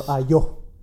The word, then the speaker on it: Ayo: Ayo. Is that how you pronounce it? – Yes. – Yes. – Or Ayo.